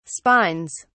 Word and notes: spines: British English

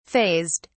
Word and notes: fazed: British English

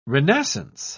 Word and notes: renascence: British English

